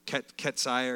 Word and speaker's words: Keziah: Keziah, it's actually pronounced Keisha. We see today pronounced as Keisha, but it's Ketziah or Keziah.